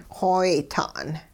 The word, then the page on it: xooyta'n: hupa